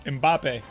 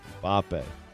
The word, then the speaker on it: Mbappe: Please pronounce that correctly. – I did. – What did you say? – Killian. – Uh-huh. – Mopey. Moppy. Moppy. – Mbappe. – Mbappe. Mbappe. That's an M, though.